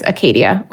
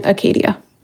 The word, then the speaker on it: acedia: So anyways, this isn't that, the start of that series, but I am talking about one of them, which is listlessness, better known as Acadia or Achadia or Acedia. I prefer the pronunciation Acadia, but so John Cashin learns about the eight evil thoughts from Evagrius, who's his elder.